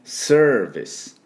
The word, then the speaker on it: service: サービスじゃない、 Service、 V で、 ER はチューズのね、 service、 service、すで終わってくださいね。これもサービスと U をつけないように。